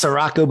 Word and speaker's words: Ciorrocco: It's Ciorrocco, but that's what my friends call me, C-Roc, Stephen.